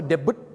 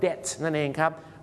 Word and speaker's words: debt: debut ด้วยนะฮะบางคนบอก debut debut นะฮะเขาออกเสียงว่า debt นั่นเองครับ